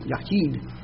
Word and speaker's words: Yachid: And that word is Yahid. If you want to spell it in English, Y-A-C-H-I-D, Yahid.